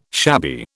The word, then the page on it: shabby: British English